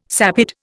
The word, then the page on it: sapid: Indian